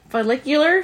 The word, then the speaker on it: follicular: Follicular phase? – Yeah, follicular phase.